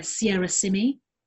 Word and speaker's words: Sierra Simy: And on Instagram as well, where I go by the moniker Sierra Simi. I can spell that S-I-E-R-R-A-S-I-M-Y.